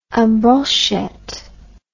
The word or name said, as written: en brochette